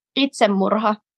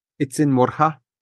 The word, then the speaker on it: itsemurha: Itsemurha. – Itsemurha? – Yes. – Was that decent, my pronunciation? – Yeah. – Yeah, it was.